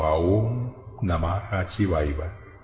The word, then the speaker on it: Om Namah Shivaya: El mantra de la nueva era de Acuario para los siguientes 2160 años es Om Namah Shivaya. – Om Namah Shivaya. – Puede ser pronunciado también como Aum Namah Shivaya.